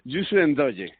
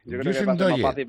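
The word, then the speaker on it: Youssou Ndoye: Yusen Doye. – Yusen Doye. Yusen Doye, pues tal y como se escribe, ya está.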